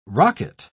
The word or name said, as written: rocket